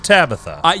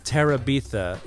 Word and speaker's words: Terabithia: The Bridge of Tabitha. – Yes. I always want to say Terabithia because I think... Terabithia. Yeah. It's spelled exactly like the name Tabitha, except... There's an ER, which means emergency room.